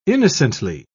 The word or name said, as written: innocently